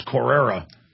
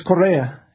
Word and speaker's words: Correa: So I was mispronouncing it. It's Correa. – Yeah, it's Correa.